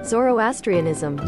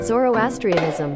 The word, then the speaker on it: Zoroastrianism: How do you pronounce this? It's Zoroastrianism. – Zoroastrianism? – Zoroastrianism. – All right, that's interesting. I mean, it's literally how it's spelled phonetically.